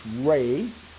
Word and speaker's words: Rei: And it's called R-E-I, and pronounced Ray.